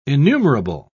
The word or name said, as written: innumerable